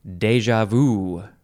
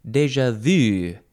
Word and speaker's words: déjà vu: The first word in this episode, the first section of page 329 is, it's a good one, déjà vu. I guess some people also say more Frenchy, déjà vu, déjà vu. It is spelled D-E-J-A, that's the first word, and the second word is just V-U, and there are accents on the E and the A in the first word.